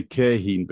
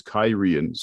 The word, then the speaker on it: Cairhien: I always used to say Cairhien, but it's Kyrian, so yeah.